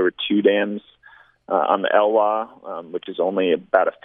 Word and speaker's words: Elwha: Before we close, I do want to have you tell me about your trip down the Elwha River. Is that how you pronounce it? – Yeah. – In Washington? – Elwha, up on the Olympic Peninsula of Washington.